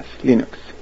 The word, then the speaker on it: Linux: and I pronounce Linux as Linux."